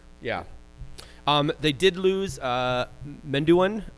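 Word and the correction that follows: Medunjanin: Guy, his names are fucking terrible. Terrible to pronounce. – Majunin, yeah. Thank you.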